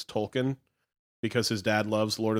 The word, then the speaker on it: Tolkien: Tolkien, not Token. Tolkien. – His name is spelled with an L.